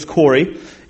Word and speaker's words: Corey: My name is Corey, and just so you know, I spell my name right.